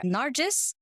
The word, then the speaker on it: Nargis: So for everyone there, Nargis, a lot of people struggle to pronounce my name, so that's why I'm like better to clarify it.